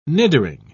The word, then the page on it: nidering: British English